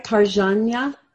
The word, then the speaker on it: Parjanya: You know what? When you think about it and you sound it out, which you did, you got it exactly right. Because it's phonetically correct the way it is. Right, so you had it right, Parjanya.